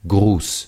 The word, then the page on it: compliment: /ˈkɑmpləmənt/; /ˈkɒmplɪmənt/